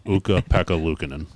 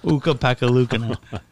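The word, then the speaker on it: Ukko-Pekka Luukkonen: Is that how you say it? – That's not even close. – No, is it? – No. Uka Pekka Luukkonen. – Uka Pekka Luukkonen.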